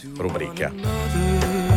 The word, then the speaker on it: rubrica: Comunque, rubrica, caro Leo, il dizionario DOP, quindi dizionario d'ortografia e di pronuncia, vuole che si dica rubrica.